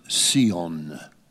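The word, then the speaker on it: Sion (ציון): So, by the rivers of Babylon, where we wept well, as the psalm goes, when we remembered Sion, Sion is how you say it in the Jewish language. Eretz Sion, the land of Zion.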